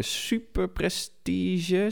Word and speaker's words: Super Prestige: In Gieten wordt er nog gereden voor de Super Prestige, zeg ik dat goed? Ja.